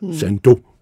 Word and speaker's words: Sandoz: Sandoz, udtalt på fransk, schweizisk.